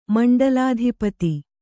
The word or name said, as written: ಮಂಡಲಾಧಿಪತಿ